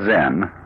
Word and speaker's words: Zen: This word Zen is Japanese way of pronouncing the Chinese word chan, which in turn is the Chinese way of pronouncing the Sanskrit word jhana.